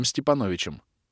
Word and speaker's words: Степановичем: Первого звали Иваном Глебовым, второго — Сергеем Степановичем. С ударением на «о», поскольку фамилия, а не отчество.